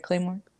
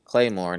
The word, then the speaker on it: Claymore: That's how you pronounce it, right? Claymore? – That's exactly how you say it. Claymore, not to be confused with those things that you set down in Call of Duty to blow people up.